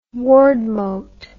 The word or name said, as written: wardmote